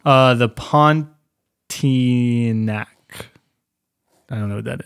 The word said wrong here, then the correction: Pontianak: Is that how you pronounce it? – The Pontianak. – Pontianak.